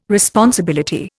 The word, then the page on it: responsibility: Indian